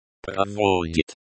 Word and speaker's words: проводит: Проводит. Проводит, conduct slash is conducting. This is a verb in the present tense, third person singular. The stress is on the second syllable. Проводит.